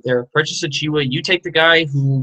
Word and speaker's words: Achiuwa: All right, Precious Achiuwa.